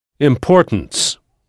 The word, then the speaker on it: importance: Ça s'écrit exactement comme en français. Mais comme vous le savez déjà, il n'existe pas de son naso un et en anglais. Donc, on entendra importance.